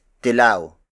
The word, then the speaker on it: dilaw: dilaw dilaw Dilaw.